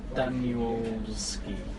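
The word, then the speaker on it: Danielewski: Mark Danielski.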